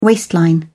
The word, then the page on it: waistline: British English